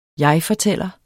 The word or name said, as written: jegfortæller